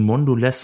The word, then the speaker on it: Mondelez: Qualcomm legt seine Quartalsergebnisse vor, ebenso Kraft Heinz, dann die beiden Schokoladenriesen Mondelez und Barry Callebaut.